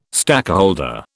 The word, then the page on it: stakeholder: British English